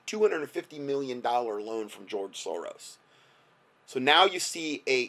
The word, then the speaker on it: Soros: The first person that broke that story on Kushner and in his involvement with George Soros, and yes, I said that correctly, with a $256 million loan with a huge development that they put together, Cadre.